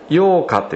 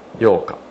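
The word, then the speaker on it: 蛹化: サナギね、これをサナギになると書いて幼化って言うんですね。幼化、そしてサナギや幼虫からダイレクトに成虫になるものもいますが、これを羽化って言うんですね。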